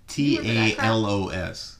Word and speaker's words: Talos: It's T A L O S.